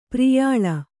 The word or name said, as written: ಪ್ರಿಯಾಲ